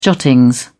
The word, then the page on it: jottings: British English